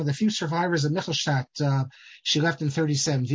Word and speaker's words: Michelstadt: And the correct pronunciation is Michelstadt, with German. But in Yiddish, we say Michelstadt, because it's with a chaf.